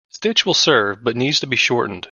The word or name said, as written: shortened